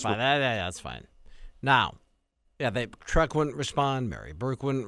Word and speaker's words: Trek: So when your company's name is Trek, which rhymes with wreck, which makes you think of Mary Burke, a Trek wreck, as it were, then it's okay.